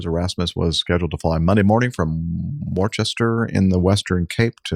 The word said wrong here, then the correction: Worcester: That'll be Worcester. – Worcester, excuse me. They pronounce it like that in South Africa too, huh? – Well, pretty much everywhere. – They copy the British pronunciation.